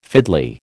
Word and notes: fiddly: American English

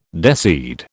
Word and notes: deseed: British English

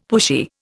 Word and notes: pushy: Indian

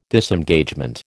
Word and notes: Disengagement: American English

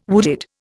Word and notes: wooded: Indian

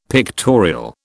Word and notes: pictorial: British English

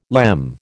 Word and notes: LEM: American English

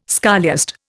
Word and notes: scholiast: Indian

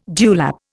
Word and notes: dewlap: Indian

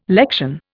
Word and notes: lection: Indian

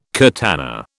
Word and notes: curtana: British English

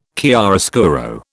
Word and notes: chiaroscuro: British English

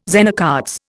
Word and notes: Zener cards: Indian

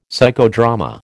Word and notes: Psychodrama: American English

